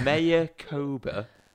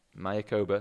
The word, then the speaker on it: Mayakoba: The Mayakoba Golf Classic. – Mayakoba, there we go.